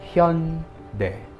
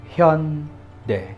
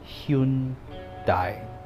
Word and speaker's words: Hyundai: The correct pronunciation of that is Hyundai. Hyundai. Hyundai. But because of romanization, many people thought it's Hyundai. Hyundai. Hyundai. Hyundai. Know the difference.